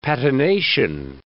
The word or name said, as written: patination